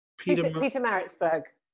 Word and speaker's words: Pietermaritzburg: Pietermaritzburg.